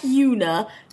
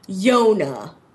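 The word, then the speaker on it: Yonah: No, not like Yuna. Yona. It's spelled like Juna. – But no, it's spelled like Jonah. – Okay. – But with a Y. So it's Younah.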